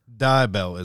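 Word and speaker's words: Dubbel: Dubel is how it's pronounced. – It's D-U-B-B-E-L, Dubel? – Dubel.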